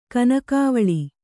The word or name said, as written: ಕನಕಾವಳಿ